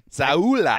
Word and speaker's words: Sowell: Yeah, he's an upgrade on Bradley Sowell, or Sowell, however you say his name, who was starting. – Saula. – I mean, Fletcher Cox and Brandon Graham are... – That's the correct pronunciation.